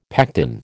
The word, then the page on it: pectin: American English